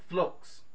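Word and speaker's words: phlox (φλόξ): You pronounce it in the Greek, phlox.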